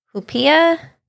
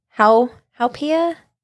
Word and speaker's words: Haupia: I'm going to go with the Haupia. Haupia. Haupia. – I think in Hawaiian, you're supposed to pronounce every a vowel.